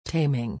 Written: taming

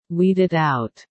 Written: weeded out

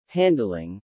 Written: handling

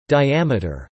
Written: Diameter

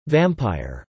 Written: vampire

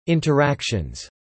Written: interactions

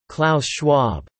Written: Klaus Schwab

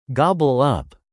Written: gobble up

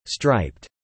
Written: striped

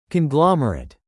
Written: conglomerate

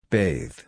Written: bathe

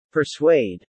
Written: persuade

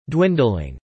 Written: dwindling

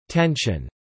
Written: tension

